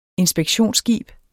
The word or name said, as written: inspektionsskib